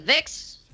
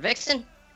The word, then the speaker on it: Vixxen: Is it Vix or Vixxen? – Either's fine.